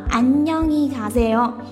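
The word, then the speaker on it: 안녕히 가세요: 来，我们看一下我们的第一个哈，读作 안 녕 히 가 세 요。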